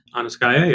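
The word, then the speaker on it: Anuskaya: Like I'm going to pronounce it wrong, but the Lays of Aniskaya. – Aniskaya, yeah.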